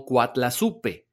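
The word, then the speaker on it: Coatlaxopeuh: usó el término azteca de Coatlaxopeu, el cual, cuando es pronunciado, suena como Coatlazupe. Por lo tanto, suena muy parecido al español Guadalupe.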